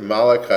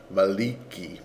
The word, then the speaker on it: Maliki: Or when I do, like, for example, we know that, Maliki, people pronounce it Malachi as if it were like an Eastern European name, but it's not. It's an Arabic name, and I think the correct way to pronounce it is Maliki.